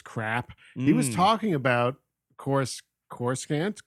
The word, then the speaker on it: Coruscant: What saying that I was pronouncing Coruscant completely wrong, and it's Coruscant, and you know, stop saying it wrong.